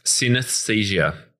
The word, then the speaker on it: synesthesia: and it's all about synesthesia. Now, have I said that correctly? – Synesthesia.